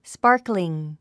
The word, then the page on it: sparkling: spάːrkliŋ; スパァクリン